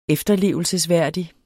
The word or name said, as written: efterlevende